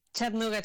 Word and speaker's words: Chattanooga: en Chattanooga, en el estado de Tennessee. ¿Lo estoy pronunciando bien? ¿Cómo has dicho? Chattanooga. – Perfecto. Sí, Chattanooga, Tennessee.